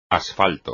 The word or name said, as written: asfalto